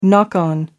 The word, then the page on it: knock on: American English